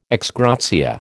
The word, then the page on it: ex gratia: American English